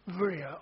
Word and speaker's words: Berea: In modern Greek, B is pronounced with a V. So they say, Berea, I